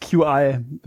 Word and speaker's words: Qi: Best QI. Wir müssen es ab jetzt Qi aussprechen, weil das sagt die englische Qi. Allwissende Müllhalde. – Ach komm, das heißt wie Qi.